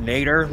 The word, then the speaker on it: Nadir: If Nader? We pronounce it Nader, like the direction on a ship, Nader is down, Zenith is up.